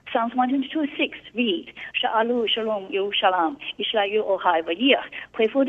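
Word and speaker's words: Yerushalayim (ירושלים): But the vocalization, the vowels indicate the pronunciation is Yerushalayim, which is a dual ending, Yerushalayim.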